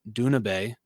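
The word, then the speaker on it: Doona Bae: Dune Bay as Duna Bay. Oh, I guess that's how you say her name. My bad. They told me wrong.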